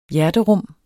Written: hjerterum

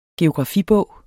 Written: geografibog

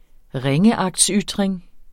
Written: ringeagtsytring

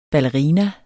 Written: BALLERINAER

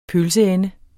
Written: pølseende

